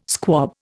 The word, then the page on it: squab: Indian